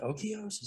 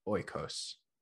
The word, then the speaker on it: Oikos: So is it Okios? Is it Okios? – No, it's definitely like Oikos. It's Greek. Oikos.